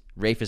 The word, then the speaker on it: Ralph: Ralph is how you pronounce his name, everybody, not Ralph. – Yeah, he's Welsh descent, and that's how you say Ralph.